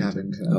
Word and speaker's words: Shavington: The other way of saying it is Shavington, but the locals say Shavington. Shavington.